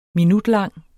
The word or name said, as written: minutlang